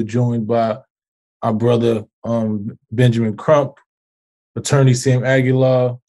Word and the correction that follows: Aguiar: Aguiar. – The L is silent, right? Aguiar. Our other co-founders of Until Freedom, Angelo Pinto, Linda Sarsour.